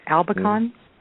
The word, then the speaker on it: albicans: I have struggled with Candida, and I'm hoping I pronounce this right, albicans. – Yes, Candida albicans,